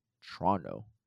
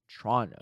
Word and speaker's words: Toronto: If you want to practice your pronunciations, locals say Toronto as Toronto, right? T-R-O-N-N-O. Emphasis on the Tron part. So Toronto. Looking forward to next week.